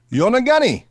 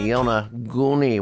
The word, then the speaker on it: Yonaguni: So Yonaguni, I think is the way he pronounced it. I think so. We'll go with that anyway. There you go, Bad Bunny, Yonaguni.